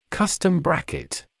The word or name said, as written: custom bracket